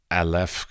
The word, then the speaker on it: Aleph: Aleph Contemporary. Did I pronounce it correctly? – Yes, you did.